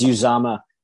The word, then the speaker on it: Uzomah: Well, they now have a surprise, kind of a surprise weapon in CJ Uzomah use mama. – I think Trevor sent through how to pronounce it, but I call him Uzoma and Uzama. – That's how I'm gonna. – It's Uzama, but I just love.